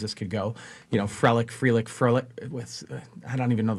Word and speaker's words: Frelick: Sal Frelick, am I saying his name right? – It's Frelick, yes.